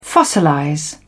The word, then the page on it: fossilize: British English